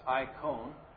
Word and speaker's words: icon: Image. You'll remember from last time, is icon. It's pronounced icon.